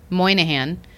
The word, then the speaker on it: Moynihan: Have you been to the new Monahan Station? I don't know how to pronounce it. Moynihan.